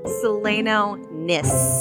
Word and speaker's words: Seleno Nis: Seleno Nis. With an S.